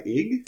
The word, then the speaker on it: YG: It's spelled YG. I don't know how you pronounce that. – Ig. – There you go.